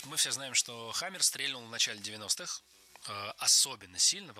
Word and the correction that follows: стрельнул: Стрельнул – это неверно. Правильно, стрельнул. Хотя в разговорной речи вариант с ударением на первом слоге я слышу часто. Это, можно сказать, такой сленг. И даже грамотные люди так иногда говорят, привнося в это слово иронию. Но литературная норма – стрельнул.